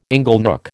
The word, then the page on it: inglenook: American English